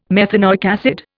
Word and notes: methanoic acid: Indian

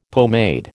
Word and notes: pomade: American English

